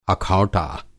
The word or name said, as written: अखौटा